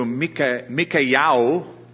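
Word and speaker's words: Mikayahu (מיכיהו): Now the term, the name Micah, the Hebrew Mikayahu is how they pronounce it, means who is like the Lord.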